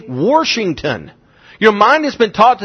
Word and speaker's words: Washington: But if you go into some places, the word C-A-N apostrophe T is pronounced can't. There is no I in that word. W-A-S-H-I-N-G-T-O-N is what? Washington, right? Not to some people, Washington. There's no R in that word.